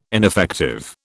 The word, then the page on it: ineffective: British English